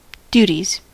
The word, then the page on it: duties: [ˈdju.tiz]; /ˈdu.tiz/; /ˈdjuː.tiz/